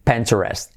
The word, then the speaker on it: Pinterest: ¿Cómo se pronuncia en inglés? Pinterest. Y como en letra clave R.